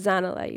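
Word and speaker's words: Zanele: So I look back at Mrs. Niels and I'm like, "Oh, actually, it's pronounced Zanella.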